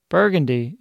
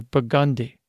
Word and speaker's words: Burgundy: Burgundy is not like the wine or the region, but rather Burgundy.